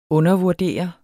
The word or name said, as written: undervurdere